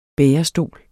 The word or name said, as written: bærestol